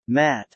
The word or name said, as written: Matte